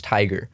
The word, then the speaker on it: Tyga: It's pronounced Tiger.